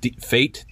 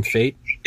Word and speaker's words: Fait: Fait, Dean Fait. – Yep, just as fate would have it.